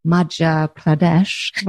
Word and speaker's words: Madhya Pradesh: but the state of Madhya Pradesh.